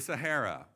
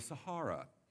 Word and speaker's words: Sahara: The Sahara, or the Sahara. There's two pronunciations.